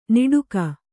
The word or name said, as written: ನೀಟುಗ